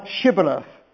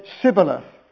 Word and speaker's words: Shibboleth: And so we read in verse 5 that to each person they said, "Say now Shibboleth." And he couldn't do it, could he? He said Sibboleth. For he could not frame to pronounce it right.